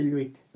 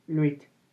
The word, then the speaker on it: Lwyd: Creo que se pronuncia así Luit. – Ajá. Es L-W-Y-D. Luit.